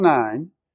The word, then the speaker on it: Nain: So he's gone to a city called Nain. I know it doesn't look that way, but that's the way it's pronounced.